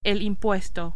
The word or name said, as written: impuesto